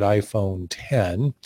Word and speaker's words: iPhone X: Well, it's the symbol X, but they pronounce it iPhone 10.